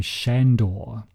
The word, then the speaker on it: Sandor: Everyone in the film, including the father himself, pronounces his name Shandor, but it is spelt Sandor in the film's credits.